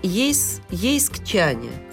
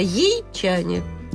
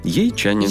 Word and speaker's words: ейчане: И почему в Ейске, хочется сказать, ейскчане? Это нам задают вопрос. Но вот мы сами не знаем, как называют жителей Ейска. Хотя вариант у нас вот ейскчане, но очень сложно произносить. Называют жители Ейска, по мнению одной из наших слушателей, очень просто ейчане.